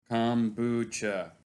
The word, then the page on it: Kombucha: Slowly